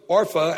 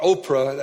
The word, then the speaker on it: Orpah: The name of the one was Orpah, and the name of the other Ruth," not Oprah, as I sometimes have been known to pronounce that, it's Orpah.